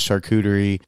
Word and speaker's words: charcuterie: But then as I have matured as an adult, I've learned the proper pronunciation of it, and it is charcuterie board, and you need to have that as a snack to go in before you get your full plates.